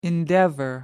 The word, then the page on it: endeavour: American English